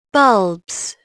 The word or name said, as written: bulbs